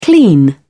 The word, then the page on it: clean: British English